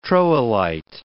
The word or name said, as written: troilite